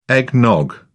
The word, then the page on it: eggnog: British English